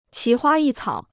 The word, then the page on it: 奇花异草: qí huā yì cǎo